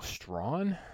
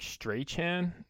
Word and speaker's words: Strachan: Paris Campbell, the highest in terms of routes run, with 30, 23 for, I believe his name is pronounced Michael Strawn. It looks like Strachan, but I was listening to Red Zone and they pronounced it. I believe it was Strawn, so we're gonna go with that for now.